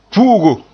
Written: pu'ugo

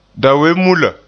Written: dawenmulla

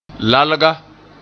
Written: lalga

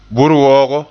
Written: burwoogo

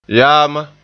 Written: yaama

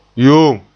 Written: yoom

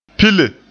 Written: pile

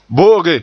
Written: bo'oge

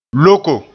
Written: loko

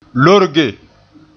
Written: lorge

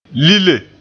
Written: lile

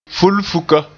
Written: fulfuka

